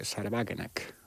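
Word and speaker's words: Sahra Wagenknecht: es el de Sarebagenek, o como claro, se diga en alemán, ¿no? – Está bien pronunciado, ¿eh? – Sí.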